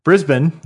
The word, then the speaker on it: Brisbane: So in Brisbane, is that how you say it? – Nailed it.